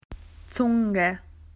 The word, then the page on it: tongue: Australian English